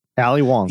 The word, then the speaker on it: Ali Wong: Am I saying her name right? – No, yeah. Ali Wong.